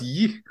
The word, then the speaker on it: Ye: So is it pronounced Yeezus? – No, it's Ye.